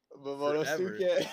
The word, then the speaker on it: Momonosuke: I was like, oh my god, I've been saying that wrong. – Momonosuke.